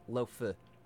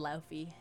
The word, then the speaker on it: lo-fi: So it's lo-fi.